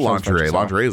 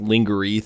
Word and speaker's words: lingerie: I think all lingerie is a French word, right? French made, that's where that comes from, right? I'm assuming. – Hey, it's pronounced lingerie. Thank you very much.